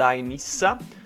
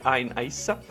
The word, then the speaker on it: Ain Issa: L'area al centro dei combattimenti rimane quella attorno ad Ain Issa, leggera Ain Issa, si legge Ain Issa, dove numerosi tentativi di avanzata sono stati respinti dalle forze democratiche siriane.